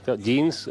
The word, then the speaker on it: jeans: El motivo, que claro, ella habla de jeans, jeans, o sea, genes y jeans vaqueros se pronuncia en inglés exactamente igual.